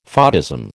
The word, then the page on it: photism: American English